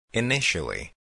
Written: initially

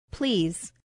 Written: please

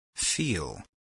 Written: feel

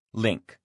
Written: link

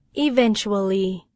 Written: eventually

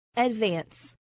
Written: advance

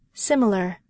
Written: similar

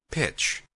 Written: pitch

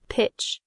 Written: pitch

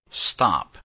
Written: stop

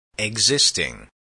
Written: existing

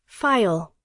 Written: file

